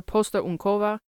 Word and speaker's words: posterunkowa: and she is a constable or posterunkowa,